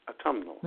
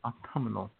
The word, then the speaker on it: autumnal: Autumnal. – Yeah. Autumnal. – No, you're putting an extra syllable in there. Autumnal.